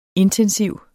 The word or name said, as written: intensiv